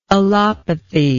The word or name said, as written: allopathy